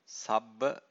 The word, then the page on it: sabba: Pali